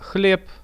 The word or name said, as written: hleb